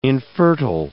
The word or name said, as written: infertile